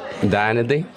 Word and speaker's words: Dayanidhi: Dayanidhi.